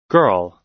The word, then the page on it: girl: American English